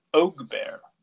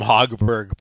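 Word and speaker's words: Högberg: Linus Ogbear. That's how you say it? – Linus Ogbear. – Ogbear. Huh. – So it's like a silent H and a silent RG.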